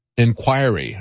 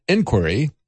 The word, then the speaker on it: inquiry: I had a caller call in and explain to me that I was putting the emphasis on the wrong syllable. It's not inquiry, it's inquiry.